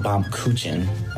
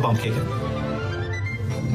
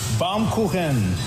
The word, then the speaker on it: Baumkuchen: I'm pretty sure it's along the lines of bomb kuchen. I just feel like that's right. – Bomb kuchen? Bomb? How about Baumkuchen? – No, guys, he pronounces it like Baumkuchen. Baumkuchen. Otherwise translated, tree cake.